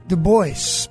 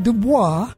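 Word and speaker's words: DuBois: Du Bois, Pennsylvania, not Du Bois. Du Bois. He clearly says that it's pronounced the American way, not the French way.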